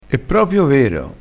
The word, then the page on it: propio: dialettale